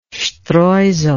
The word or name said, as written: streusel